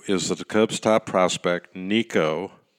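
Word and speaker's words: Nico Hoerner: Yes, it rhymes with Rico, and that would be Rico Petroselli, Rico Cardi, and Rico Crime if you're a lawyer in the strike force. But anyway, Rico, that rhymes with Nico, Nico the shortstop that took the place of all those other injured Cubs, Nico Horner.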